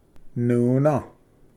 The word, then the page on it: ᏄᎾ: nuna